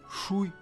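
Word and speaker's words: Шуй (水): Шуй – вода. В третьем тоне.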